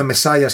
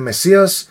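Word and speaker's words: Messias: Το λέγαμε Μεσάια και μου λένε, μου λένε ότι είναι Μεσία.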